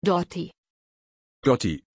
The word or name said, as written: Dotty